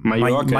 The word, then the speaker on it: Mallorca: Mallorca, wie sagt man eigentlich? Mallorca oder Mallorca? – Mallorca. Mallorca. Zwei L spricht man wie ein J.